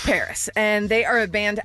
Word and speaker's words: PVRIS: I picked a band that I've liked for a while, but this song is brand new by Paris. You might know them. – Paris Hilton? – By the way, they spell it P-V-R-I-S, so people might think, oh, maybe it's Pavrudis. It's not.